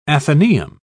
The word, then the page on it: atheneum: British English